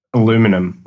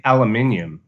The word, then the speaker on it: aluminium: We were talking about how the Brits say vitamin or schedule, but then what do you say, aluminum? – Aluminum. – Okay. – But no, we say aluminium. – Aluminium, yeah.